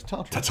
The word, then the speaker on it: Tartarus: And so, like, the idea of having a Tartarus version of Earth, is that how it's pronounced? Tartarus? – Tartarus. No, it's Tartarus. – Tartarus. – I've always pronounced it Tartarus.